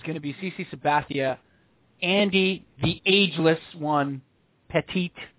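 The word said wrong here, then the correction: Pettitte: Uh, are, actually, I believe it's Pettit. I believe it's pronounced Pettit.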